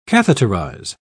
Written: catheterize

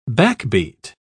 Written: backbeat

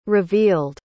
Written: revealed

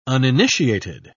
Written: uninitiated

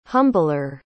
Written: humbler

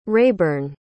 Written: rayburn

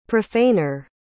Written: profaner

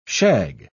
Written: shag

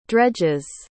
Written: dredge's

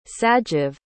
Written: sagiv

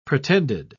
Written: pretended

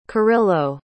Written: kyrylo

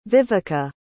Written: vivica

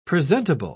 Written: presentable

